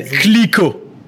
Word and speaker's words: Clicquot: So the correct way to pronounce it is veuve. So veuve. And then Clicquot.